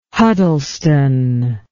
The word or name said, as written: Huddleston